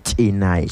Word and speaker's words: chinay: Entonces pronunciamos chinay.